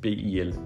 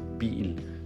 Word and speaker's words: bil: For eksempel ordet bil, bil. Der er sådan en lille, hvad skal vi sige, et stød i udtalen bil, eller jeg hedder, eller jeg taler engelsk.